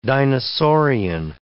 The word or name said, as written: dinosaurian